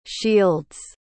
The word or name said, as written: shields